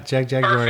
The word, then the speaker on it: Jagjaguwar: so, do uh, this release is uh, your first one for Flemish Eye, as opposed to uh, I never know how to say the name of the other label, Jagjaguwar. – That's it. Yeah, Jagjaguwar.